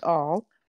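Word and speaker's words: all (אל): But if you look at this right here, the Aleph and the Lamed, it can mean strong leader, pronounced all, or it can mean chief shepherd.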